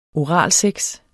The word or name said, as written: oralsex